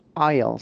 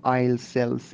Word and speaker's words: aisle: معمولا بچه ها خیلی دوست دارن که کنار پنجره بشینن، پس window seat and aisle seat. Repeat after me. Aisle seat. دقت کنید که S در اینجا اصلا تلفظ نمیشه. نمیگیم aisle cell seat.